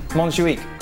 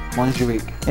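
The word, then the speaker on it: Montjuïc: Montjuic. – Montjuic.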